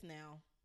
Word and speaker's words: Nao: Nao or Now. I don't know. Somebody tell me how to pronounce her name. – I think it's Now. – Okay. Thank you. – I'm pretty sure it's Now.